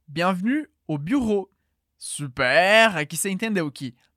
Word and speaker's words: bureau: São só duas sílabas. Bureau. Aqui na segunda parte da palavra, a gente tem o som do R da garganta seguido de O. Então é ro.